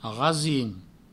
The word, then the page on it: rasim: provençal; rasin